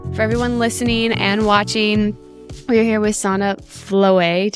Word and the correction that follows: Vloet: Oh, Vloet. I was like doing it the French way.